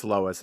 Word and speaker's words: Lois: I think it's Lois and not Lewis. I think it's Lois.